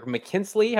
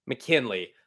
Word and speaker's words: McKinley: So we go from Mikal to Tack McKinley or McKinsley. How do I pronounce his last name? – McKinley.